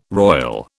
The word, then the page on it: roil: British English